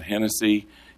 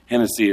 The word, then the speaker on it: Hennessee: Matt Hennessy. Hennessy is spelled not like the cognac. It's spelled like the state of Tennessee, H-E-N-N-E-S-S-E-E, without the Y.